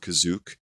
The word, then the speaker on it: Kashyyyk: called Kazook, as it's pronounced here.